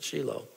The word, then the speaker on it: Shiloh: At the time, we thought it was Shiloh until I got to Israel and heard, no, they pronounce it Shiloh. So I'm pronouncing it Shiloh, which is that, how they would pronounce it there.